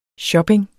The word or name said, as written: shopping